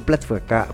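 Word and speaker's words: pletyka: Sono atmosfere piuttosto inquietanti quelle evocate da questo trio, che si traduce come pettegolezzo, pletvka, questo significa in ungherese, non lo sapevate? Io sì, è scritto pletica, però ho scoperto che la y in ungherese si pronuncia come v.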